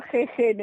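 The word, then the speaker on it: jején: Es que la gente lo pronuncia yeyen, pero es verdad que se escribe jejen y deberíamos decirlo jejen.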